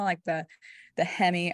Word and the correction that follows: heme: iron, or am I pronouncing that right? The hem iron? – Heme. – I knew I say it wrong. I'm like, is it hem or heme?